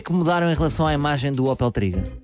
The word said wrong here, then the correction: Tigra: Tigra. – Até que ponto é que falhas na pronúncia do nome?